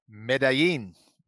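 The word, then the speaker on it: Medellín: Medellin. Medellin.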